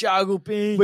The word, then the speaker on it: Thiago Pinto: Thiago Pinto. – Which, hey, you know what? That's how you say it.